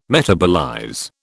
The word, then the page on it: metabolize: British English